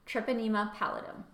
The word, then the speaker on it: Treponema pallidum: So syphilis is a bacteria called Treponema pallidum. Did I say that right? – Treponema pallidum.